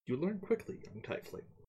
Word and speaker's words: tiefling: The correct pronunciation is tiefling, good sir.